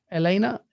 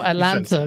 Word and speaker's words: Atlanta: Elena? Yeah, Elena. – Oh, the teaser. So, oh, start, okay, I see what you say. – Oh, Atlanta, because he doesn't pronounce the T's.